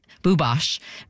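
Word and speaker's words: Bubas: Bubosch, it's spelt though, K-A-R-I-N-B-U-B-A-S, K-A-R-I-N-B-U-B-A-S.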